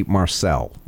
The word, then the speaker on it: Marcel: The gentleman's name is Jesse Marcel, not the way I was pronouncing it before. – Okay. – Because I confuse him with Chicken Marsala.